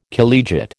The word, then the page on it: collegiate: American English